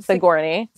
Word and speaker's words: Sigourney: Sigourney.